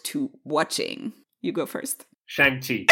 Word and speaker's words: Shang-Chi: It's, it's, it's Shang. It's Shang Chi. It's not.